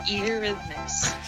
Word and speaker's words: Eurythmics: Eurythmics.